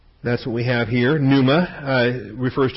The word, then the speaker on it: pneuma: So point four is pneuma. P-N-E-U-M-A. It's a silent P, pneuma.